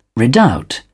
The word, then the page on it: redoubt: British English